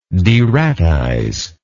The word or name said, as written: deratization